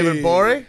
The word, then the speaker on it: Gborie: David Gborie. – Hey. – AKA the G is silent.